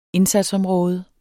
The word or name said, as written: indsatsområde